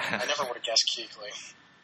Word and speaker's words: Kuechly: So, you guys have the scariest thing possible, which is Luke Kuechly. And we don't know if we're pronouncing his name right. – You are. That's right.